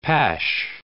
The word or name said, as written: pash